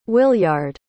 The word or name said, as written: williard